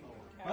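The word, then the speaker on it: kauri: How do you say quarry tree? – Cowrie. – Cowrie.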